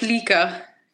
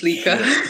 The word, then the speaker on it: Lika: So in Georgian, like the letters, the way that you see them and the way that you write them is literally how you pronounce them, and that's it. So it's just Lika.